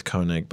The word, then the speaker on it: Koenig: Well, Stephanie and I, Stephanie Koenig, one of the great geniuses on this earth, Koenig is spelled K-O-E-N-I-G. It's actually pronounced Koenig. The problem is she taught it to me as Koenig when we met because she was experimenting with going with Koenig, but actually it's Koenig.